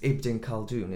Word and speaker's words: Ibn Khaldun: But you actually selected, and now pronunciation's Ibn Khaldun, isn't it? – Yes.